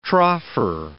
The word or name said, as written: troffer